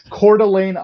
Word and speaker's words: Coeur d'Alene: He's based in, help me with his pronunciation, Coeur d'Alene. What is that? – Coeur d'Alene, Idaho. – Coeur d'Alene. Yeah, you're like, it's much simpler than you're trying to make it.